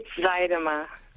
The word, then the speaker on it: Zuidema: Abby, maybe you should say your last name. – It's Zuidema. – Zuidema, forgive me.